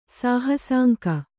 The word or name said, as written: ಸಾಹಸಾಂಕ